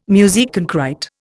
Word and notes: musique concrÊte: Indian

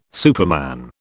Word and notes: superman: British English

